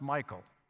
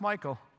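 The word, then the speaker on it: Michal: But I have a hunch that David, first wife, and actually, I'm sorry that her name is pronounced Michael. I got into a debate with one theologian many, many years ago when I was young and foolish, and finally he went out and did the research and said, well, yeah, you're right, it's pronounced Michael.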